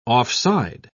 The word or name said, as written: offside